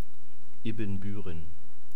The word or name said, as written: ibbenbueren